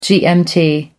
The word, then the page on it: gmt: American English